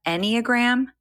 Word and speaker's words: Enneagram: With an emphasis on traditional coaching and astrology, she often supplements with knowledge from the Enneagram. Is that how you say it, Natha? Enneagram? – Yeah, that's how I say it.